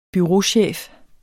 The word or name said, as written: bureauchef